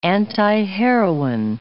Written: antiheroine

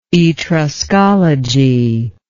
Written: Etruscology

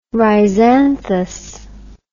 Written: rhizanthous

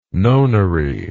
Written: nonary

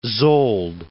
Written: Szold

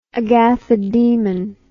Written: agathodemon

